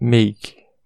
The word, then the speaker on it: make: No inglês a gente vai ter muitas vogais mudas no final de palavras, tá bom? Então a pronúncia é make, tá bom?